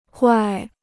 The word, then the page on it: 怪: guài